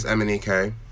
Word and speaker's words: Emenike: So my surname is Emenike, and it's spelled E-M-E-N-I-K-E.